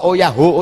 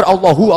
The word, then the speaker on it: Allahu: Bapak kok oh yahu, oh yahu, oh yahu. Sing bener Allahu, Allahu ngono, Pak.